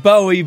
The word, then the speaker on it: Bowie: No, it's spelled Bowie, but it's pronounced Bowie.